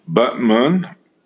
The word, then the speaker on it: Batman: Pronounce Butmun, B-U-T-M-U-N, in November of 2008.